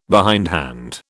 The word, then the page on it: behindhand: British English